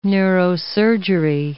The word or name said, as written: neurosurgery